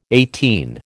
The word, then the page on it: eighteen: American English